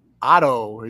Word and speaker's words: OTTTO: the band OTTTO. Is that the right pronunciation? – Yep, that's correct.